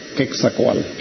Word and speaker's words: Quetzalcóatl: ¿Cómo se pronuncia? – Quetzalcóatl.